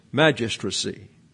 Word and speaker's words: magistracy: Magistracy, it's hard to do this because the emphasis is on the M-A. And then everything else is not emphasized. So it's magistracy.